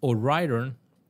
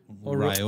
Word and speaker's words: O'Riordan: Tras la marcha del Neil Queen en 1990 fue Dolores O'Riordan ¿Sí se pronuncia así? Riordan. – O Riordan.